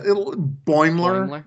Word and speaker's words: Boimler: Actually, someone else on Twitter posted a picture that they made of Simon Pegg as Scotty and Jack Quaid as Bulmer. Is that how you say his name? – Oh, my God. Yeah. Boimler, I think.